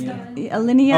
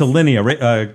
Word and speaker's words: Alinea: My yup goes to a restaurant called Alinea. I think that's how you pronounce it. – I think so. – Or Alinea. It's Alinea. – Oh, it's Alinea.